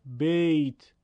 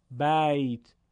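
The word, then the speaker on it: بيت: کلمه بیت، اگر با لهجه فارسی بگیم میشه بیت، بیت غلطه در زبان عربی، باید بگیم بیت.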